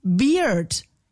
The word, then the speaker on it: beard: Dann allerdings kommt komplette Verwirrung bei mir im Kopf, denn wenn man an B-E-A-R, an Bear, noch ein D ranmacht, ist es nicht Bird, sondern Beard. Ja, Beard.